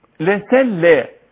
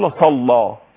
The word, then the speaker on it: لسلطهم: ولو شاء الله لسلى، قلت والنطق والتلقي لسلى، لان البعض يقول لصلى، لص استقبالك للطاء قلت لصلى وهذا خطا، لسلى لسلى طاء هكذا مش ليس لصلى،